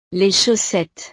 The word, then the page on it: les chaussettes: frances